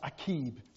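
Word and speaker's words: Akib (עקב): And this bit here sounds like a word that is pronounced Akib. So can you say to the person next to you, Akib? Akib, tremendous. So Akib means heel.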